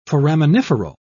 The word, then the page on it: foraminiferal: British English